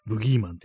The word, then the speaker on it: 武器男: う武器男と書いてブギーマンって読ませるっていうね、なんかこう、当て字ですね。